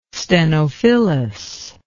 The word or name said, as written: stenophyllous